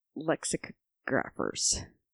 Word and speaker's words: lexicographers: Is it a long O or a short O? – It's lexicographers. Because it's lexicon.